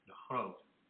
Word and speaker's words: de Groot: And that average was obtained from a paper that was published by Dutch chess grandmaster Adriaan de Groot, or as it is pronounced in Dutch, de Groot.